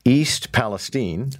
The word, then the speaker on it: East Palestine: Okay, well, let's listen in for a second here, because the craziness in all of this is East Palestine,